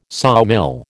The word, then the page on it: sawmill: American English